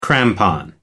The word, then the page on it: crampon: American English